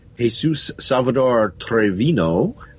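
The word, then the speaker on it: Jesús Salvador Treviño: Okay, Sons and Daughters, Season 6, Episode 3, directed by Jesus Salvador Trevino. – Wow. Well done with that pronunciation.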